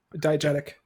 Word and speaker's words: diegetic: Much of the film's sound is diegetic. I believe that's how you pronounce that. – Diegetic. – Damn it. – Diegetic. It's okay.